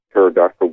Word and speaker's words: pterodactyl: Why can't you hear a pterodactyl going to the bathroom? Because the P is silent.